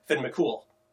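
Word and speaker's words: Fionn mac Cumhaill: Finn McCumul, but it's because of the pronunciation, the grammar rule, it becomes Finn McCool.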